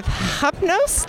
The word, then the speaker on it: Hypnos: He is with Hypnos. Did I say that right? – That sounds right.